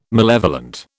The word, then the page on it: malevolent: British English